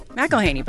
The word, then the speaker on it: McElhenney: How do you pronounce it? – McElhenney, but nobody says it that way.